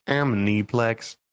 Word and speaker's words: Aniplex: Well, you can say it incorrectly, or you can learn how to read and say Aniplex. – It's not about reading. When you listen, it's Aniplex.